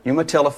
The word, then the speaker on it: Umatilla: I hope I said that right. Umatilla, Florida. – You got it.